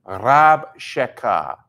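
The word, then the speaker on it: Rabshakeh (רבשקה): It's spelled Resh Bet Shin Kuf Hey, Rabshakeh.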